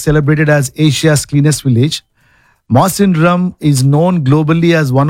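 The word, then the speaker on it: Mawsynram: Tura, Jawai, and Masandone, and I'm pretty sure we are not pronouncing it right. – You're not pronouncing it right. – Oh, do you think so? – Because I went to YouTube and said how to pronounce. – Oh. – It's Masandrum.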